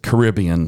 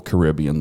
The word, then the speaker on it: Caribbean: But the other is Caribbean, like we pronounce Mediterranean or Epicurean. And Royal Caribbean, the cruise line, pronounces it that way.